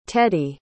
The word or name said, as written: teddi